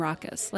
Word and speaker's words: ROCIS: The group goes by the name Raucous, like Raucous Noise or Raucous Party, but it's spelled R-O-C-I-S.